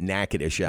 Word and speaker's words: Natchitoches: If you didn't have any background about the city, there's no way you could pronounce it correctly. – No, you look at that spelling, there's no way you get Natchitoches out no.